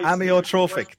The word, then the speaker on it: amyotrophic: Amyotrophic. – Yeah, that's why. – Amyotrophic.